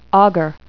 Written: augur

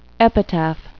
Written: epitaph

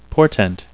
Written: portent

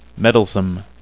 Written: meddlesome

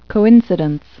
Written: coincidence

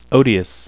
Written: odious

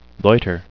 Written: loiter

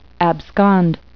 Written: abscond

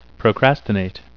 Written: procrastinate

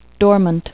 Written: dormant

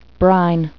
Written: brine

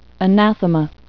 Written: anathema

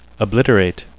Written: obliterate

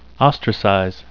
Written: ostracize

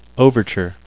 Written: overture

